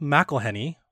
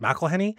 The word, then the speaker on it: McElhenney: The other thing I want to talk about at Ubisoft was Rob McElhenney, or I thought his name was Rob McElhenney, but he pronounced it McElhenney.